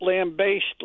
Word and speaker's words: lambaste: Both pronunciations are correct, but lambaste, like you baste a turkey, I think that's the preferred pronunciation.